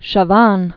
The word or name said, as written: Pierre Puvis de Chavannes